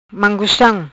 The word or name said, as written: Mangustam